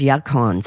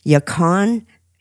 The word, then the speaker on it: yacon: The first is yacon syrup.